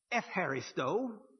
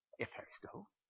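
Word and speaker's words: Epharisto (ευχαριστώ): In modern Greek, this is pronounced like an F, and the CH is pronounced like this. So you get, it says, how do you say thank you in Greek? Epharisto. Epharisto, like a man's name. Epharisto. And so if you want to say thank you in Greek, you say Epharisto.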